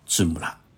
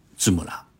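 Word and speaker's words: ツムラ: 金村公司的正式名称叫芝木拉，这是日语“ 金村”的发音，用的是片假名表示，就是念作芝木拉。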